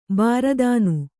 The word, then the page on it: ಭೀರುತನ: bhīrutana